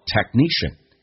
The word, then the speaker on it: technician: 第一个听懂一个单词叫 technician，技术人员，重音是在后面，前面那个可是不用发音，叫做 technician。 The technician will come ASAP。那第二个你要能听懂这个缩写 ASAP，叫 as soon as possible，马上就过来。这句话我们重新来慢速的读一遍。 Don't panic, I press the call button. The technician will come ASAP。